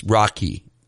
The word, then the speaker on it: Roky: It's spelled R-O-K-Y, and, I'd been pronouncing it Rocky. Apparently it's Rocky, just without the C.